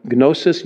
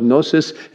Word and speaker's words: gnosis: There's it's not always exactly, you know, in these two categories, but there's a Greek word that gnosis, you don't usually pronounce the G in that as it's typically spelled, gnosis, and epignosis or epignosis.